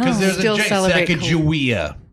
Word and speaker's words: Sacagawea: Sacagawea? – Sacagawea is actually... – Excuse me? – The proper pronunciation.